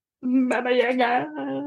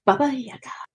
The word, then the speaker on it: Baba Yaga: Yeah, in the end of it, and she like I tried 7 times at least, and she kept correcting me, and I'm like, you know how sometimes you say something like send and sent, and it sounds the same to you. Trying to get me to pronounce Baba Yaga correctly, I can't hear the dip in the vowel.